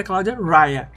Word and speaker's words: Cláudia Raia: Mas a pronúncia correta é Cláudia Raia.